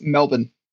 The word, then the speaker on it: Melbourne: A little heads up on the pronunciation. It's Melbourne. If you can make your body do that, it's Melbourne. It doesn't look like it, but that's how we say it.